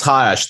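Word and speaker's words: Ha*Ash: Like a lot of the other tracks captured some of the other covers captured some of the other tracks, is the, forgive me if I'm pronouncing this wrong, and I don't think I fully got it. Ha-Ash? – I think it's Ha-Ash.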